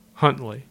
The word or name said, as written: Huntley